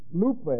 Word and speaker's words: lupe: The word lupe, L-U-P-E, long E, means sorrow or pain.